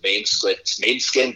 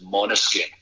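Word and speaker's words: Måneskin: Everyone says Mainskin, but I believe it's Monoskin.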